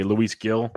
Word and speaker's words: Luis Gil: Luis Gil, is the way you pronounce it by listening on TV.